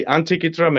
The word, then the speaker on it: Antikythera: Antikythera Mechanism.